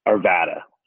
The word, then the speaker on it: Arvada: Okay, so you go back, and it's for this position at NEF, but within a couple of years, you're at, sorry if I don't pronounce it right, Arvada, Arvada? Which one? – Arvada.